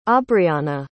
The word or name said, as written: aubrianna